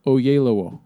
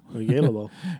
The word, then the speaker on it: Oyelowo: Actually, that name is pronounced how it's spelled. Oyelowo. – Oyelowo.